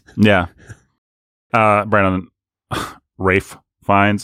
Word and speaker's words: Ralph: He pronounces it Rafe. – Wraith Fiennes. – Wraith. It's not Wraith. – Wraith. – Maybe for one of our Halloween. Rafe. – Wraith. – Rafe. – Wraith. – No. Rafe. – Wraith. – No. There's an F sound at the end, not a T.